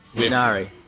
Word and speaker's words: Minari: Minari, am I pronouncing it right? – I think that's right. Minari.